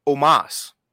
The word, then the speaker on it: Omos: But, I keep mispronouncing this motherfucker's name. – I thought it was almost. – Omos. – Omos. Shit, there it is. Omos.